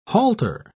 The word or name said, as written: halter